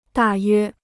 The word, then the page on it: 大约: dà yuē